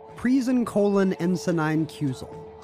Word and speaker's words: Prisencolinensinainciusol: Prisencolinensinainciusol. – All right. – Did I say that right? – Yeah, it's a colon maze and say one Prisencolinensinainciusol.